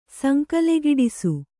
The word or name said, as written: ಸಂಕಲೆಗಿಡಿಸು